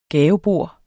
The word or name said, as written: gavebord